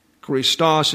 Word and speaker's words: Christos: Christos is emphasized on the last syllable.